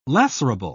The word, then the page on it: lacerable: British English